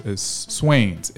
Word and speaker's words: Swanes: Like, I pronounced it Swanes. So it's Swains. It's Swains. It is Swains. So it's all capital S-W-A-N-E-S.